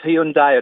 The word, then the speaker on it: Hyundai: It's pronounced Hyundai. It always is. It's dropped the I, so it's not Hyundai. It's not Hyundai. It's... Hyundai. – There's a Y on the end of it.